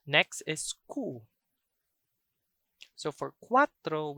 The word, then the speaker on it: cuatro: So that's the quick kuwa followed by the last syllable, tro. Cuatro, cuatro. So that would be 24, 24. But make sure to thrill your R. Always remember, in Cebuano, the R is always thrilled.